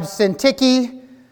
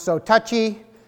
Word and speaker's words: Syntyche: We have Euodia, sometimes intentionally mispronounced Odious, and then you have Syntyche, sometimes intentionally mispronounced, so touchy.